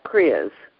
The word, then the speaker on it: kriya: Now, in your book, you talk about, and I don't know how to say this, so I'm going to spell it, K-R-I-Y-A, cleansing exercises. First of all, how do you say it? And then what is it? – Yeah. So it's pronounced Kriya.